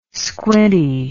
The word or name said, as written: squinty